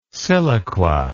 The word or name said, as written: siliqua